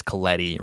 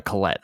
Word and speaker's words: Collette: If you like Toni Collette, and I believe I'm saying her name right, I don't think it's Colletti, right? – I have no idea. – It ends in E-T-T-E, so it's probably Collette, right? – Yeah.